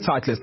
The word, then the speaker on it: Titleist: Titleist. – How much? – Sky Titleist. – It is Titleist.